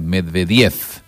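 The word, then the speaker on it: Medvedev: La mayoría de los apellidos son con acentuación esdrújula, excepto Medvediev, donde la última V se pronuncia F, o sea, Medvediev.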